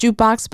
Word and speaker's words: JKBX: Now, it's pronounced Jukebox, but it's spelled JKBX, in 2022 as founding chief executive officer and investor.